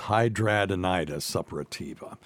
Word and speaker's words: hidradenitis suppurativa: hidradenitis suppurativa. – Okay. You'll learn to pronounce that one in medical school. It's hidradenitis suppurativa.